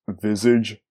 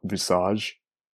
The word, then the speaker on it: Visage: So I realized while editing this episode that it's pronounced visage, not visage.